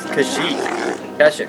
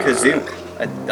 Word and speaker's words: Kashyyyk: Maybe that's Kashyyyk. – Maybe he mispronounced it. – He said Kazook. I think if I heard him correctly.